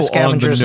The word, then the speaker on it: SCVNGR: Seems a little Scavengers on the nose, Network. Sir. – Yeah, though he also created it before us, but hey, whatever. But actually, it's spelled S-C-V-N-G-R, so it's like Scavenger.